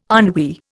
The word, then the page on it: ennui: Indian